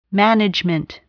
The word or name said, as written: management